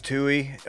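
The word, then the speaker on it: Tuohy: Is that how you say it? Tui family? – I think it's Tui.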